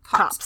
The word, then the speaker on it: copse: A copse of trees? Cops. I've never actually heard that word said out loud. Thank you. – If I'm wrong, I'm wrong, but I'm pretty sure it's cops. – Because it's not a word that you see people use very often anymore. – Hold on. Google will tell me how it's pronounced. – Like, I know what it is. – It is pronounced cops. Okay.